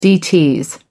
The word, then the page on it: dts: American English